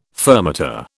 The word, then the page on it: fermata: British English